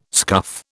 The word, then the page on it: scuff: British English